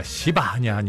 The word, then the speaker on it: 시바: 발음에 주의해야 하던데, 시바. 그를 다윗의 앞으로 부르매 왕이 그에게 말하되, 내가 시바냐 하니?